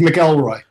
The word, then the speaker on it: McElroy: It's McElroy.